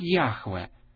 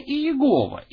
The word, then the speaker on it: Яхве: Таким образом, мы не знаем, какие там были гласные, поэтому большинство сходится на мнении, что это должно произноситься как «Яхве». Но где-то, на какой-то момент возникло произношение «Иегова» или «Яхова», и оно стало довольно распространенным. Но «Яхве», вероятнее всего, является наиболее правильным или более близким к истинному произношению, хотя точно этого никто не знает.